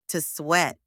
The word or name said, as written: to sweat